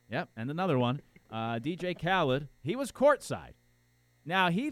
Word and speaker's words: Khaled: DJ Khaled.